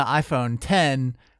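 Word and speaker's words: iPhone X: Then the iPhone 10, which has a X in the name, but it's pronounced 10 because Roman numerals.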